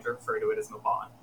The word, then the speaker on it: Mabon: how do you pronounce this like ridiculously Welsh name? And he told me, and it's Mabon.